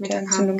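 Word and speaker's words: Metacam: Metacarm? – Ja, das ist Metacarm, genau. Ich dachte, das sei falsch ausgesprochen. Nein, richtig.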